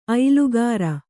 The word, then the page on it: ಐಲುಗಾರ: Kannada